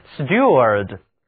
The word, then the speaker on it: steward: 还有单词 steward， S-T-E-W-A-R-D，这里字母组合 E-W 读音是 U，而组合 A-R 读倒写的元音 A。